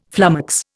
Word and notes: flummox: Indian

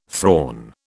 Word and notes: thrawn: British English

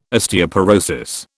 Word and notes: Osteoporosis: British English

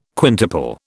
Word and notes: quintuple: British English

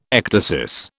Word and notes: ecdysis: British English

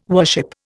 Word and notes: worship: Indian

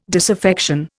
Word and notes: disaffection: Indian

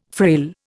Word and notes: frail: Indian

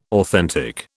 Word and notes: authentic: British English

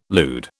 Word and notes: lewd: British English

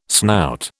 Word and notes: snout: British English

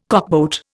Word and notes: cockboat: Indian

